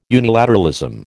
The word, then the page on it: unilateralism: American English